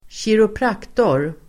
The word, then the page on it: kiropraktor: [²tjiropr'ak:tor]